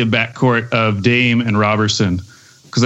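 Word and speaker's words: Roberson: He's Robertson. A robber because he steals.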